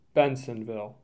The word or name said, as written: Bensenville